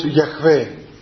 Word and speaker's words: Γιαχβέ: Γύρω στο 12ο αιώνα περίπου οι Ραβίνοι οι Εβραίοι υπελόγισαν ότι αυτά τα σύμφωνα ε, προφέρονται ως Γιαχβέ.